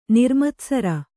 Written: ನಿರ್ಮತ್ಸರೆ

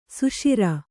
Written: ಸುಷಿರ

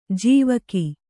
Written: ಜಾವಕ್

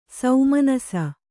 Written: ಸುಮನಸ್ಸು

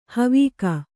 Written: ಹಾವಿಗೆ

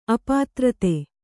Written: ಅಪಾತ್ರತೆ

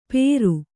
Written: ಪೇರು

